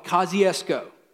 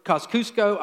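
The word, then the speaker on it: Kosciusko: Some people really mispronounce it, but it's called Kosciuszko. You ever heard of Kosciuszko, Mississippi? I've heard it Kosciuszko. I've heard it, you know, all kinds of crazy stuff.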